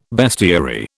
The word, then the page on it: bestiary: British English